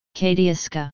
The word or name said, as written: katiuska